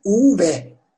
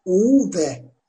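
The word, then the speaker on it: uve: U harfi normal U, V harfinin okunuşu uv. Uv.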